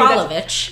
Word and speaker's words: Kralovich: Kralovich.